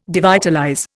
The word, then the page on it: devitalize: Indian